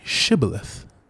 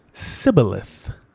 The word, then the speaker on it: Shibboleth: And then the people of Gilead said, well, say Shibboleth. And then when the Ephraimites spoke, they said Sibboleth. And so because they didn't say it right, they killed that fool.